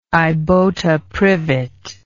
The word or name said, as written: ibota privet